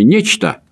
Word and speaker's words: нечто: Не затруднит ли вашего гостя объяснить разницу между словами «нечто» – ударение на первый слог – и «ничто» – ударение на второй слог?